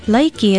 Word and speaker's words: laiki: Laiki is how we say rice in Hawaiian, and since we who live in the islands eat so much of it, you should know how to say it in Hawaiian.